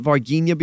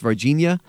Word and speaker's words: Varginha: I've called it Varginha before. I went with a hard G. So Varginha, Varginha, tomato, tomato, but I believe it is actually pronounced Varginha.